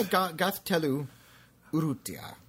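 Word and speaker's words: Gaztelu-Urrutia: No, Gathelu Urrutia. – Okay. – Yeah, just, that's how you pronounce it.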